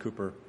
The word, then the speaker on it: Cowper: William Cowper, it's spelled Cowper, it's pronounced Cooper, who wrote "There is a Fountain Filled with Blood" and a dozen of our favorite hymns.